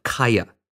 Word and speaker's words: Qyah: Her parents run a trading post in the fictional Alaska town of Kaya, that's spelled Q-Y-A-H.